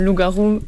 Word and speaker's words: Loup-Garou: Loup-Gaou, je ne sais pas si je prononce bien. – Oui, bah, c'est comme Loup-Garou.